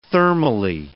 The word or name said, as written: thermally